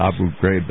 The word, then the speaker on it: Abu Ghraib: So what you guys, and also the other point that people seem to overlook is the folks that are in Abu Ghraib, I'm going to call it Abu Ghraib, that's how they say it here, these are pretty evil people.